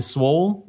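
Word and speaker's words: Swoole: That being said, PHP, again, evolving, came up with Swole this year.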